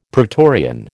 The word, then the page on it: pretorian: American English